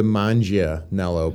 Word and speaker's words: Manganiello: But people try to do the Manganiello, but it's not, it would be M-A-N-G-I-A. – Right. – But it's not G-A as manga. – It's not the soft G, it's the hard G.